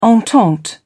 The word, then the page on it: entente cordiale: British English